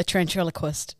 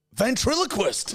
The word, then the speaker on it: ventriloquist: Ventriloquist.